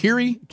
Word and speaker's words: Kyrie: Is that how you say it? – Kyrie. – That's right. Kyrie.